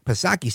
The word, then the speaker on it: Psaki: It looks like Jen Pacircle back Psaki. Silent P, of course.